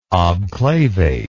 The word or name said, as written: obclavate